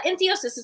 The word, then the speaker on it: entheosis: What is entheosis? – Yeah, entheosis is perfect. You pronounced it perfectly.